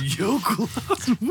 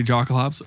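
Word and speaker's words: Jokulhaups: Yokelhops. What the fuck? I've never heard anybody call it Yokelhops? That's like the correct pronunciation. – I always heard people either say Jocklehops or Hocklops.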